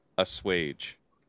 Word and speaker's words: assuage: Assuage. According to Google, it's pronounced assuage.